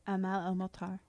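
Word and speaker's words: Amal El-Mohtar: And in there was, pronounce the whole name, Amal El-Motar? – Amal El-Motar.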